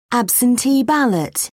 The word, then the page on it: absentee ballot: British English